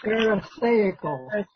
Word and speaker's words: pharisaical: If anyone would mind helping the pronunciation. – Pharisaical. – Okay, thank you.